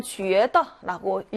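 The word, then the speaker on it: 쥐에다 (觉得): 여기 보시면 쥐에다라고 읽는데요. 여기도 유 땡땡 발음으로 읽어야죠.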